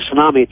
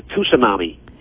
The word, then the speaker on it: tsunami: In 1 week in that tsunami, which is not really a tsunami, it's a tsunami. People don't pronounce the T.